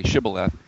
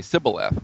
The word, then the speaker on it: Shibboleth: And only a Gileadite could say it properly, Shibboleth. And the others would say Sibboleth with an S sound.